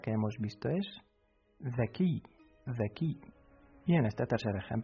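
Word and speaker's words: the key (ذكي): Se pronuncia the key.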